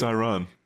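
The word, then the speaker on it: Ayran: I don't know how you pronounce it. Is it Iran? – It's Iran.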